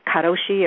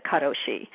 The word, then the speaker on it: karoshi: It's called, I know it's pronounced a couple of different ways, karoshi or karoshi. Have you ever heard of that? – No. Now, is that, do you get that at the sushi bar? No, I'm just kidding. – Let's hope not, and I'll tell you why. Because the word, I'm going to pronounce it karoshi, means death by overwork.